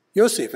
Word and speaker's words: Yosef: There are people who just insist on calling him Joe or Joseph, even after he's told them how to say his name. What do you suppose that is? Yosef, it's not that hard.